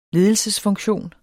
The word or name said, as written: ledelsesfunktion